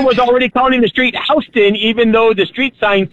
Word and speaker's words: Houston: The way we pronounce it is correct. The reason it's called Houston Street in New York is because the person they named the street after, his name was Houston.